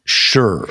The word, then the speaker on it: shih: Now it's pronounced it's spelled S H I H, I believe. – It is. It is. That's the Chinese. – Right. That's the transliteration, tra- transliteration in English, but it is pronounced sure.